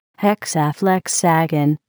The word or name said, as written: hexaflexagon